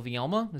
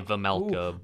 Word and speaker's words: Vielma: Coyotes, I don't think they played the night before, but we got Carl Vielma. Is that his name? – I believe that's how it's pronounced. I say Vemelka, but I guess not. – By the way, well, I don't know if there's a K in his name. That's why I say Vielma.